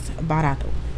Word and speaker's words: barato: So like I said, the word is barato. – Barato. – And it rhymes with the word Naruto. Naruto.